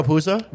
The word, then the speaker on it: Puzo: But it was kind of funny that this movie, like, they talk about when Ponzi, I can never say his name. The guy who wrote the Godfather book. – Oh, Mario Puzo? – Mario Puzo, yeah.